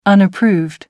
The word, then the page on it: unapproved: American English